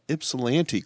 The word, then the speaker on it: Ypsilanti: What's weird about the pronunciation is that you got Ypsilanti correct.